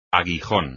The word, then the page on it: aguijón: espagnol